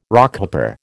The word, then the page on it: Rockhopper: American English